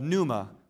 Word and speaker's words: pneuma: In the New Testament, in the Greek, it's called pneuma with a silent P, right?